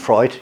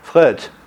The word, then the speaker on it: Freud: You'll notice that in French we often like to make the pronunciation French. So the founder of psychoanalysis in French is Freud. Whereas in England we prefer to approximately imitate the original, and so we say Freud.